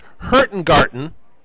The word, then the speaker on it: Hurtigruten: So it's it's pronounced Hurtengarten. – Right. – So H U R T I G R U T E N.